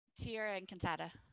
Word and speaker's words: Tierra Encantada: So talk a little bit about the company you have, Tierra Encantada. Is that how you say it? – Tierra Encantada.